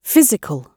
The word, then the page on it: physical: British English